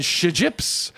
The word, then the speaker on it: Shjips: Now, Wooden Ships is spelled SHJIPS. Imagine the trouble they have with the MLC. Anyway, they're called the Wooden Ships, but Shijips.